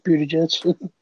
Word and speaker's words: Buttigieg: Yeah, Pete Buttigieg.